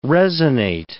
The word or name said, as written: resonate